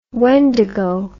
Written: wendigo

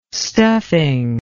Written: stuffing

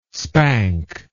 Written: spank

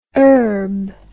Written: erb

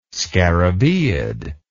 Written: scarabaeid

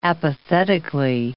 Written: apathetically

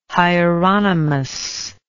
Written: Hieronymus

